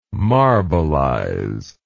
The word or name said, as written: marbelize